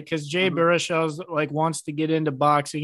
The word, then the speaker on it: Baruchel: It's either two or three punches because he takes out your boy, Jay Baruchel. Is that how you say his name? I don't know. – Yeah, Jay Baruchel. Baruchel. Yeah, that's correct.